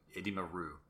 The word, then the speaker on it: Edimaru: I pronounce Ru like Hugh. Edimaru. Edimaru.